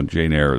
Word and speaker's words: Jane Eyre: Oh, Jane Eyre is what it is, yes.